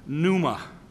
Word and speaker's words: pneuma: He uses a word pneuma, P, silent P, N-E-U-M-A.